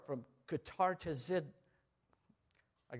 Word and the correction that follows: katartizo: You try it. K-A-T-A-R-T-I-Z, long O, D. Katartizo. I got it.